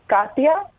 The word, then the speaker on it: Katia: It's Katia. – Katia. Katia. – K-A-T-I-A.